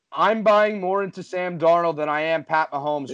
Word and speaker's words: Darnold: You called him Darnold there,